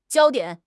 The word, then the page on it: 焦点: HSK6